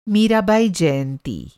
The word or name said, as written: मीराबाई